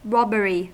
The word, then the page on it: robbery: American English